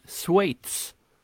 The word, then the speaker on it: Schweiz: Jag har sagt rätt hela tiden. Jag vet inte, Per, han i förra podden hånade, men Schweiz. – Aha. – En gång till. – Schweiz.